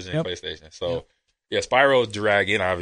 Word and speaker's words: Spyro: Spyro the Dragon. Am I saying that right? – Yeah, you're saying it right. – Spyro the Dragon.